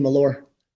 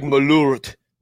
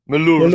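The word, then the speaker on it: Malört: I just realized Corey Furlong points out that I forget that everybody doesn't – you know, everyone remembers that I don't pronounce the T in Malore. – Malore. Malore, L-O-L. Yeah, it should be like Malort. Malort.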